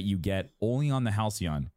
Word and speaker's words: Halcyon: Halcyon, yeah.